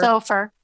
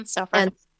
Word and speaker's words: SOFR: And, you know, there's the conversion going on between LIBOR and how do you say that? SOFR. – SOFR. Mm-hmm. SOFR.